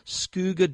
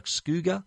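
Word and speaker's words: Skugga: But Skooga Dynamic Eyewear, I don't even know if I'm pronouncing that right. It's spelled S-K-U-G-G-A. I'm trying my best. So that, to me, sounds like Skooga, the Dynamic Eyewear.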